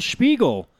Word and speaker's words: Spiegel: Like, wow, good job, Danielle Spiegel. Fucking very impressed. You even said it right.